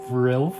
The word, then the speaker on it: RiLF: I believe that's how it's pronounced. It's capital R, lowercase I, capital L, capital F, in the true style of Japanese band names with specific capitalization. So I believe it's pronounced Rilf.